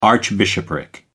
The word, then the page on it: archbishopric: American English